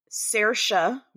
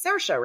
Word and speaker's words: Saoirse: And then I'm going to pronounce this as correct as I can. Tell me if I'm right. Saoirse Ronan, who's my favorite. – I think that's right. – It's Saoirse, right? – Saoirse.